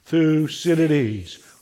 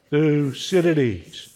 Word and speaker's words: Thucydides: Thucydides. One more time. Thucydides.